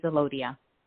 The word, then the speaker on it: Alodia: Alodia? – Yes, it's Alodia.